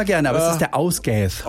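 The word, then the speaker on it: Ásgeir: Wäre der Ausgath, so spricht man ihn aus, ein isländischer Sänger.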